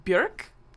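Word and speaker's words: Björk: It's Bjork. Rhymes with jerk.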